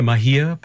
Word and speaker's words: Mahia: Am I pronouncing it correctly? The Mahia Peninsula? – That's correct. Yeah.